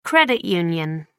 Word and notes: credit union: British English

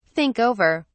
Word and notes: think over: British English

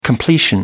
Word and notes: completion: American English

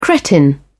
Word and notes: cretin: British English

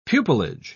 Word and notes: pupilage: British English

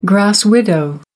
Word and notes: grass widow: American English

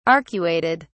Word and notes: arcuated: British English

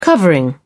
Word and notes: covering: British English

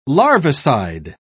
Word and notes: larvicide: British English

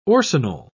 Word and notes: orcinol: British English

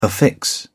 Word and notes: affix: English; British English

